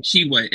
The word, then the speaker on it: Tshiebwe: Tshiebwe. It's just Tshiebwe. The T is silent.